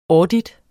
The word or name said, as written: audit